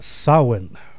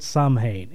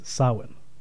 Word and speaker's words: Samhain: Yeah, and it's pronounced Samhain. It's a Celtic word. – Okay. – Now, the more you know, it's not Samhain, it's Samhain.